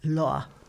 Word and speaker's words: Lohr: L-O-A. All right, Renata, you've done German. How do you say L-O-H-R? Law thank you, was a famous centre for glassware and mirrors, and Sophia's father owned a local mirror factory.